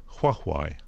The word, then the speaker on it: Huawei: It's Huawei. It's a Chinese company. – Say it again. – Huawei. – You're very good at that. Huawei.